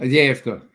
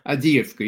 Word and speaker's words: Avdiivka: Isn't this, correct my pronunciation on it, the city, Avdiivka? – Mm-hmm. Avdiivka, yeah. Well, it depends if it's Ukrainian or Russian. Avdiivka, you're right.